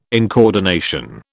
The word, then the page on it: incoordination: British English